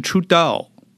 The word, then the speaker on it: Trudeau: 加拿大总理他的中文我们把它翻译成特鲁多，他英文名字的发音就是 Trudeau 啊，听起来是不是就是土豆？